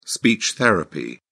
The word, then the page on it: speech therapy: British English